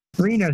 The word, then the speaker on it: Reno: Uh, just like the city in Nevada, Reno. – Reno.